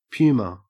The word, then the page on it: puma: Australian English